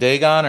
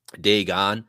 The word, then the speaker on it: Dagon: It depends. Most people would pronounce it Dagon, but Dagon or Dagon was actually an ancient Sumerian entity that has connections to the Demiurge. So historically, you'd probably say it Dagon, but the way he pronounced it and other people do, it's called Dagon, I believe.